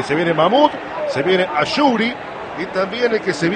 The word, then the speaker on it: Ashouri: Ashouri, me parece, ese se pronuncia correctamente.